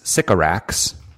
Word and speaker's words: Sycorax: Sycorax. – In Shakespeare's The Tempest. – Oh. – The name is Sycorax.